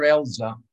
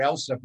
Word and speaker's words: Elsa: You know, I called her Elsa for the first 6 months I knew her, and I realized, oh no, they pronounce it Elza over there.